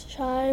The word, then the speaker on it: Chai: Oh, by the way, Chai rhymes with guy.